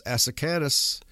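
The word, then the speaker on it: Asaacanus: Let me spell that for you. It's A-S-A-A-C-A-N-U-S, Asacanus.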